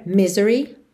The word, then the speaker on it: misery: Então o I desse Y é alongado, acaba ganhando aí uma força extra, mas normalmente sílaba tônica é a primeira, tá bem? Misery.